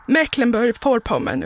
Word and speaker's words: Mecklenburg-Vorpommern: Mecklenburg-Vorpommern uttalas inte med V, vilket hördes i sändningen tidigare. Bokstaven V ska snarare uttalas som ett F i dess plats, det vill säga Vorpommern.